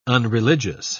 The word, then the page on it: unreligious: British English